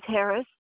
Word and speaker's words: Harris: My name is Millie Harris. – Can you spell it for me, please? – Yeah, Millie, M-I-L-L-I-E, and the last name is Harris, H-A-R-R-I-S.